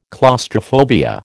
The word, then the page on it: claustrophobia: American English